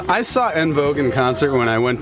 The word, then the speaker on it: En Vogue: En vogue. En vogue. Don't let go. – Did you call them En Vogue? – En Vogue. – That's the correct French pronunciation.